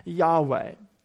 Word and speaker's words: Yahweh: That indicates that the word God here is translating God's personal name, which in Hebrew is pronounced Yahweh.